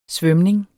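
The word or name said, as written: SVØMNING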